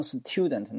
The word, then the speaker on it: student: 比如说，有个学生叫 student，student，你为什么不读成 student 呢？因为 s 后面加 t 要读成浊辅音 d 的发音啊，所以叫 student，start。